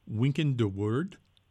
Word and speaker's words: Wynkyn de Worde: The first chapter about printing introduces the person, and forgive me if I mispronounce his name, Wynkyn de Worde. – That's good. Let's go with that.